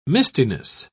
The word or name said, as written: mistiness